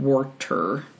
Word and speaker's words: Warter: It's not Wisdom and Water. It's Wisdom and Warter. W-A-R-T-E-R.